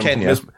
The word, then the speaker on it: Kenya: And have I been Kenya. – It's Kenya. So I've been mispronouncing this my entire life.